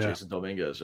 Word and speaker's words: Dominguez: All right, now, as talks have been heating up that the Yankees might call up the Martian, Jason Dominguez. Did I say his name correct? – Yeah. – Jason Dominguez, yep. – Dominguez, yep.